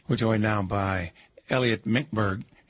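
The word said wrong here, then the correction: Mincberg: It's actually pronounced Mintzberg. – Mintzberg.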